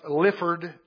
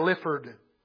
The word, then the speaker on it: Lyford: His last name may be pronounced Lifford. It's spelled L-Y-F-O-R-D.